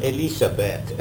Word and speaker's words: Elisabeth: E-L-I-S and then the other E-L-I-Z, and of course pronounced in German, Elisabeth.